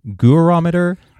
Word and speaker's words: Gurometer: So the next feature in the Gurometer, how do you pronounce it? Gurometer. You're both nodding your head.